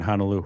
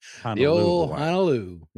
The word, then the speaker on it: Honolulu: Passed away in Honolulu, Hawaii. Excuse me. We only pronounce it with one Lou in my family. – Honolulu.